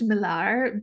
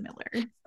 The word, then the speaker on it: Millar: It's it's my married name, but uh, my husband's family's French, and so I think it's probably like correctly pronounced Millar, but they've just Angli- anglinized it or whatever England whatever that word is. Um, yeah, so they pronounce it Miller.